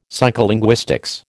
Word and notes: psycholinguistics: American English

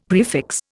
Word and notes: prefix: Indian

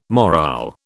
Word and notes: morale: British English

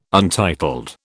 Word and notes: untitled: British English

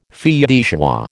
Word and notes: fille de joie: American English

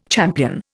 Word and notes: champion: Indian

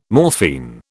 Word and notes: morphine: British English

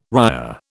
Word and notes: ria: British English

